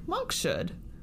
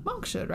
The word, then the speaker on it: monkshood: m-o-n-k s-h-o-o-d so i was like monk should yeah. Monk's hood, right? – Or monk's monk's hood. – It's monk's hood.